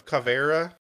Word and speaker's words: Caveira: Oh, uh, Cav- Caveira.